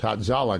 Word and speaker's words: Hatzalah: It may be the only word in Hebrew that I can pronounce, Hatzalah, because it's all A.